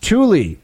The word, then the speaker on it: Thule: I'm several days behind, so sure, I'm not the first to tell you, but Thule, the U.S. base in Greenland, is pronounced Thule.